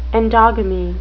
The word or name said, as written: endogamy